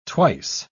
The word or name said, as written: twice